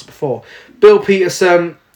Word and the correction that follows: Paterson: uh, Paterson. Wow, Bill Paterson, in the 1970s, aliens send a female android diplomat to Earth on a mission of peace.